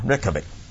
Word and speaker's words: Reykjavik: 因為如果你要照佢個發音咧，就係 Reykjavik，Reykjavik 咧就係即係雷格維克咁上下差唔多嚇 Reykjavik。